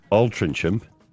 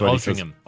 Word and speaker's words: Altrincham: It's spelt Altrincham, but it's pronounced Altrincham.